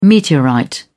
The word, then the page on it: meteorite: British English